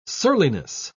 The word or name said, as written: surliness